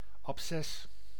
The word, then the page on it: abces: /ɑpˈsɛs/